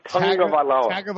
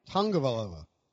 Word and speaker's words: Tagovailoa: No, but really, if you go back, I found a video of him pronouncing it when he got to Alabama, like on campus, like for the media guide or whatever. I guess they record it, and he says Tagovailoa.